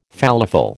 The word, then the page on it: falafel: American English